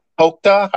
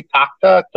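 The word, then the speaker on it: Tochta: And it's made by a company called Tokta, I think is how you say it. It's T-O-C-H-T-A, Tokta, Tokta.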